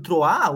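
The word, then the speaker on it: Troyes: E o Lyon é amplo favorito, inclusive, até parabenizar o Gesso aí pelo, pela pronúncia né, do Troá. Ontem tava, eu gravei o um vídeo para os jogos de hoje e eu não sabia como falar. Falei, pô, é Troé, é Troá, né?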